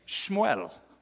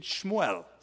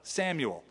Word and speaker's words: Shmuel: So it came to pass in the process of time that Hannah conceived and bore a son and called his name Shmuel. That's the Hebrew pronunciation. Shmuel, Samuel. Heard by God.